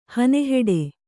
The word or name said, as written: ಹನೆಹೆಡೆ